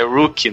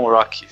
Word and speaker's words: rookie: Eu, o Eu, eu, falei errado, é rookie, não rock.